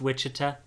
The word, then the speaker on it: Wichita: It's pronounced Wichita.